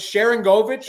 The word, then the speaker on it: Sharangovich: What's Sharangovich? – Yep.